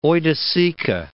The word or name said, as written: oiticica